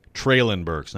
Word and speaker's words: Treylon Burks: Treylon Burks, not Treylon.